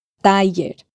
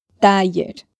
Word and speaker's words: taller: Taller. Taller. Puedes escoger la pronunciación de la doble L que te parezca más fácil y confortable.